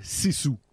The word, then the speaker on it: Sisu: Là, on passe à un autre film qui s'appelle Sisu.